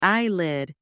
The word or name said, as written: eyelid